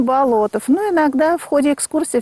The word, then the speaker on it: Болотов: Ну, как говорят, меня сегодня поправляли, что Болотов. Правильно говорить Болотов. – Совершенно верно. Александр Петрович Бердышев нам все-таки доказал, что правильно нужно говорить Болотов.